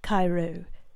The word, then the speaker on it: Cairo: Cairo is pronounced Cairo.